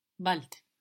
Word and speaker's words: bald: For example, Kurs in German is Kurs, K-U-R-S. – Kurs. – The word for soon was bald, bald. – Bald. – Bald is spelled B-A-L-D. A D on the end of the syllable in German is pronounced more like a T. So B-A-L-D, but what we hear is bald.